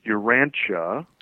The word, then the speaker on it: Urantia: And just a little correction on pronunciation. It's Urantia.